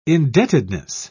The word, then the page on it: indebtedness: British English